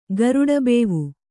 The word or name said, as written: ಗರಡಬೇವು